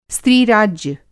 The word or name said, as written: स्त्रीराज्य